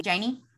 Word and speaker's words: Jaine: Jaine.